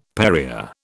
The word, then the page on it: Perrier: British English